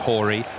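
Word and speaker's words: Chorý: Spelt Chory, pronounced Horry.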